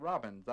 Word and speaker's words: Robbins: His name is Robbins, R-O-B-B-I-N-S, and it's pronounced just like the bird, Robbins.